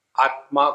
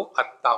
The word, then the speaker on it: आत्मा: हमारे बंगाली बंधु जोड़ाक्षर का उच्चारण नहीं कर सकते तो वो कहेंगे अत्ता, आत्मा को अत्ता कहेंगे।